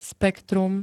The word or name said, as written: spektrum